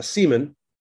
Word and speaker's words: Simon: I don't know how to say it, neither do you, but uh, for now, we'll just call him either uh, Seaman, which is the proper way to say Simon. Uh, in Norwegian, we will call him ICS Vortex, and we may call him Seaman Vortex, which you've made very bad jokes about all week.